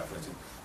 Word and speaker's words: Плотин: который был действительно Плотин, ударение на последнем слове. – Плотин? – Да, Плотин.